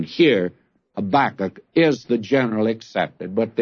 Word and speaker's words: Habakkuk: So I'm going to beat you to it here with Habakkuk. Don't write in and tell me that it should be pronounced Habakkuk, because actually, that is not the better one, or I should say, the generally accepted one.